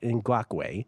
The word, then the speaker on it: Ngakoue: Yannick Jukwue, am I pronouncing that correctly? Yannick Ngakoue.